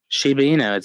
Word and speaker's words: Shiba Inu: Sheba Inu, it's like the dog.